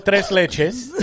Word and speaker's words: tres leches: Number one, little disrespectful with the way you're pronouncing it. – I rolled the R, at least. I gave it a shot. – It's called tres leches.